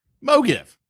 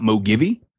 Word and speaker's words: MoGive: So not MoGivey? – Not MoGivey. It's MoGive.